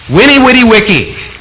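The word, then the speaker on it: veni, vidi, vici: Julius Caesar is the man who, after he had conquered an important province, said in Latin, and of course the Latin V's are pronounced as W's, "Winnie, witty, wicky, I came, I saw, I conquered." There was a misunderstanding of that that's on the board, said by somebody who didn't know Latin V's were pronounced like W's.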